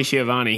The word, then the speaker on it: Schiavone: And their play-by-play announcer, you're going to have to help me with this, make sure I say his name right. Tony Schiavone? – Tony Schiavone.